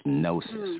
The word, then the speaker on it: gnosis: You know, when you know you're silent, the G is silent. It's gnosis, right?